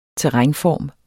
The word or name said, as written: terrænform